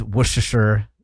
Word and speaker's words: Worcestershire: Worcestershire. Worcestershire. – It's very underrated. – That's the pronunciation. – Worcestershire. – Worcestershire. – Worcestershire. So you don't say the R, you don't say Worcestershire? – No.